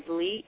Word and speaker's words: Lee: You can find us on Love Letter to My Sisters and, again, under Heather Lee. – Okay. And can you spell that slowly for our listeners? – My name? – Yes. – Is Heather, H-E-A-T-H-E-R, and the last name is Lee, L-E-E, and Love Letter to My Sisters is the name of my book.